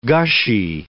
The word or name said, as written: gushy